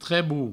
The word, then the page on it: trebo: trebou